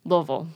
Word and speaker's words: Louisville: How's it pronounced properly? – Louisville. – Louisville. – Yep. It doesn't matter. – I know. I always, like, that's one of the cities in this country I always go, how do you actually say it? – Oh, yeah. It's so messed up. Doesn't make any sense. No. It's phonetically a disaster.